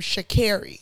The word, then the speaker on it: Sha'Carri: So she, because I saw her do it in the interview, she pronounces her name Sha'Carri.